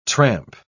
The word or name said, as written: tramp